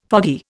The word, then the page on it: foggy: Indian